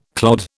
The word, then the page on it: clod: British English